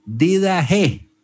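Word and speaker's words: Didaché: o me corregía mi hermano Etzvi, Didache, ¿sí? Con esa pronunciación en griego al final.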